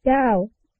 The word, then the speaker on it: Gao: My real name is spelled W-E-N-L-I. – Family name? – Gao. – Gao? How do you spell that? – G-A-O.